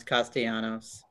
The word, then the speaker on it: Castellanos: Brett Gardner, Nick Castellanos, Andrew McCutchen. We could see a lot of great guys. – No, hold on. No, I'm not going to let you mispronounce Castellanos. – Castellanos, I'm sorry.